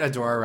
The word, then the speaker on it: Adora: Ew, okay, stop, stop, stop uh, did, did you know that Adora rhymes with Korra?